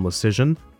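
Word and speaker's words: Luszczyszyn: The next thing I wanted to talk about was this piece in The Athletic that was written by Shayna Goldman and Dom Lecision. That is how you say that absolute mouthful of a name, by the way.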